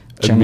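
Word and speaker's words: Chennai Express: Chennai Amazingly, Express. You pronounced that perfectly. That is exactly the way that word is pronounced.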